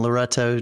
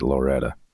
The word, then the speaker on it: Loretto: You are not. You're saying it properly. You're saying it the way it probably should be pronounced. But I know immediately you're not from there. anybody there would pronounce it Loretta, like Loretta Lynn. It's yeah. Loretta, Tennessee. And it's funny to hear anybody else pronounce it.